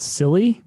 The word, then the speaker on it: Psilly: I think it's called Silly, but it's spelled PSILLY.